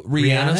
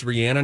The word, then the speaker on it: Rihanna: R-I-H-A-N-N-A, and it's Rihanna, not Rihanna.